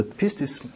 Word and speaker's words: pistis: Hvis man oversætter det på dansk, så bliver det udtalt noget i dur med pistis.